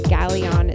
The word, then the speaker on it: Ghaleon: I believe earlier in the set I said the DJ's name was Ghaleon. It's actually Ghaleon. It's spelled G-H-A-L-E-O-N for those of you who want to Google it and find out more information about him.